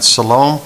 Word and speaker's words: shalom: Right, but they will be called, they will be recognized as peacemakers by the people down here, and they will, shalom is the word that comes out of this, I don't know if I'm pronouncing it right, shalom? – Shalom would be the real word. Shalom, okay.